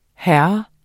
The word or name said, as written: hr.